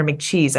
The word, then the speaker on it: McCheese: As I'm looking at some of these images, there's ones where like Ronald McDonald and Mayor McCheese. I'm saying that right? – Yes.